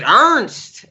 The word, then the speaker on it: Ernest: Ernest, but he would pronounce it Ernst.